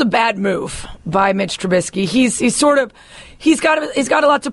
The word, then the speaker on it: Trubisky: Trubisky, Mitchell Trubisky, yes.